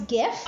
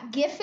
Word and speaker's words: GIF: It's okay, so it's GIF? – I'm split in between.